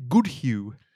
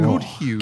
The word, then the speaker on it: Goodhue: Goodhue. – Can you spell that? – It's like good. – Yeah. – Good. And hue is in like the colour. – The colour. – Like colours. – Good hue. – Oh, right. – H-U-E.